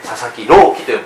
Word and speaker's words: 佐々木朗希: 佐々木朗希と読むんですね、あれ一浪二浪の浪と書いて希望の木で浪希と読むらしいんです、彼がね、プロ注目のね、 160 キロを超える速球を投げるピッチャーという、ところが決勝戦で投げなかったんです。